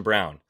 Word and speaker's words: Braun: It's Christian Brown. I know it's spelled Braun.